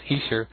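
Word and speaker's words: Esher: They played 15 songs, 5 songs from the Easter demos, and that's the way it's pronounced now. Somebody in the Beatles News and Information group the other day told me it was Easter, and I kind of looked at it and I didn't believe it, but it's very true. That's how it's pronounced, Easter.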